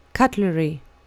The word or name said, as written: cuttlery